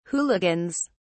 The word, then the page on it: hooligans: British English